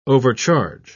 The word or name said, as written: overcharge